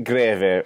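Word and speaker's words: Grewe: It's like gravy with a little bit of oomph on the first syllable. – Yeah, a little Greve.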